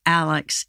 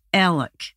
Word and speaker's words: Alec: If you pronounce Alec Murdaugh's name correctly, as they do down there, instead of Alex, it's Alec. And instead of Murdaugh, it's Murda, like murder, but no R.